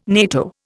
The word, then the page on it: NATO: Indian